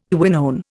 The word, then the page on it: quinone: Indian